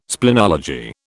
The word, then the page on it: splenology: British English